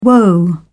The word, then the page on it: whoa: British English